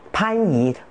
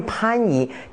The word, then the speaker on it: 番禺: 还有我们有个地名很典型的，大家都很熟悉的番禺，为什么不能念番禺呢？它是念番禺，地名还有人名还有一些族名，它都要保留上古的读音，所以这个是非常古老的，念番禺不能念番禺，这个是第一点，这是从语音上来说保留了这个重唇音啊。